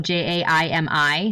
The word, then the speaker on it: Jaimi: So just I'm going to spell it out just so uh, people type it in right, but my name is spelled J A I M I, so there's no E in it.